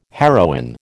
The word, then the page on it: heroin: American English